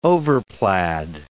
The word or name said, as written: overplaid